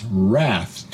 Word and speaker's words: wrath: And pronounce wrath to rhyme with bath.